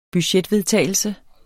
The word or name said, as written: budgetvedtagelse